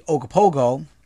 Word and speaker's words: Ogopogo: So the Ogopogo, which is the proper way to pronounce it, it's spelled quite different, is something that is in Lake Simcoe, Ontario, and has been sighted from back in 1976.